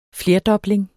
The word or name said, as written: flerdobling